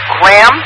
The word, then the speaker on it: Graham: I've got your call sign, KM7QSD, and it's Graham, like graham crackers, but spelled like a name.